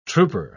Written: trooper

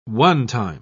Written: one-time

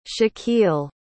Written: shakil

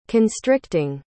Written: constricting

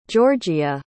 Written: jeorjia

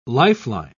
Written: lifeline

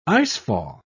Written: icefall

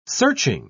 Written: searching

